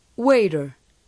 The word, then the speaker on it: waiter: Waiter, camarero. La primera sílaba está acentuada.